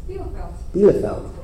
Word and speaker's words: Bielefeld: Bielefeld.